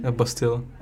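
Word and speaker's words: pastilla: How do you pronounce it again? – Bastila. – Bastila. – Bastila. – Bastila. Okay. So you miss that.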